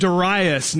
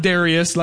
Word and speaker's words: Darius: 522 BC, a king named Darius, not Darius like the country music singer, Darius, spelled that way but not pronounced that way.